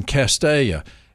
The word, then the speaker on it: Castalia: Tom is in Castalia, and for all the newscasters that come upon this word, there is an L there, but the L is silent.